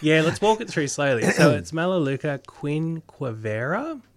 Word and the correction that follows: quinquenervia: So let's have a go. – Quinquenervia. – Oh, quinquenervia. I always drop a few syllables.